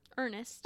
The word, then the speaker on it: Ernst: So, when Ernst, is it Ernest? – Ernest. – Okay. – Ernest Ault. – Ernest Ault. Because his name is spelled Ernst.